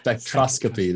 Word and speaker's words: spectroscopy: Spectroscopy.